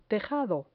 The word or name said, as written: tejado